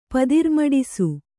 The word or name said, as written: ಪದಿರ್ಮಡಿಸು